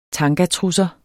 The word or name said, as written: TANGATRUSSER